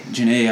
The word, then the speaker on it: Jhené: Or um, oh, Jhené, Jhené Aiko.